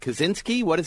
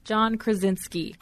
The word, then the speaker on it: John Krasinski: And this has John, is it Kaczynski? How does he pronounce his name? The office guy? – John Krasinski. – Krasinski, I got it right.